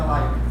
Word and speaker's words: Leica: O pessoal fala Leica. Vocês vão ver muito esse pessoal falando Leica, mas o nome correto é Leica.